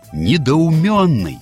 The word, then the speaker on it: недоуменный: Словарь под редакцией Аванесова не рекомендует произносить это слово через «мё». Пишет «недоуменный». И все словари для работников СМИ тоже говорят, что надо произносить «недоуменный» через «МЕ», а не через «мё».